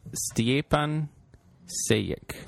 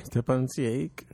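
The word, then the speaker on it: Stjepan Šejić: I'm going to say Stepan Sejic. – Stepan Sejic. – I think the J's are pronounced like Y's.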